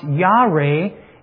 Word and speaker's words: yare: The word fear comes from a Hebrew word that's pronounced Yare, and it means to have a great reverence for, respect for God, to be in awe of God.